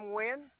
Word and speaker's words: Nguyen: Pam Nguyen.